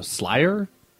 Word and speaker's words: SLYR: It's called, it's spelled S-L-Y-R, so Slayer. I figured it was, like, they call it Slayer. – I would think Slayer. – But it says Slayer, so whatever.